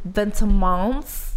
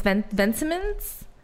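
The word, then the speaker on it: Vetements: Because it's a cute collaboration with, I feel like people pronounce it Vintimans or like some type of French way, but it's Vintimans.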